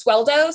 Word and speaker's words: Sueldo: And then my last name is Sueldo. So Sueldo.